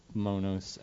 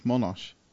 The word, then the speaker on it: Monos: I know you saw Monos. – I think it's like Monosh. It's Monosh. – Like almost like a... – Okay, like so an SH.